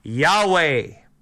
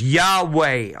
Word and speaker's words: Yahweh: Now, Previously, there was no J. That is a way of pronouncing what we call the Tetragrammaton. Yahweh. YHWH. Yahweh.